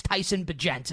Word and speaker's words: Bagent: Is it Bagent or is it Bagent? What's the official pronunciation we're moving forward with? – I have heard like many people say many different things. I believe it's Bagent. I'm willing to be wrong on that, but I think it's Tyson Bagent is how to pronounce his name.